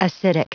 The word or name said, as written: acidic